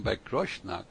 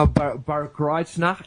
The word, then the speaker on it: Bad Kreuznach: pero yo le dije yo vivía en Bad Kreuznach. Y él me corrigió la pronunciación. ¿Cómo que se pronuncia eso? – Eso no se llama Bad Kreuznach, eso se llama Bad Kreuznach.